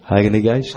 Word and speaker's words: Heiliger Geist: Is it Heiligengeist? Is that how you say it? Is that how you say it? Heiliger Geist.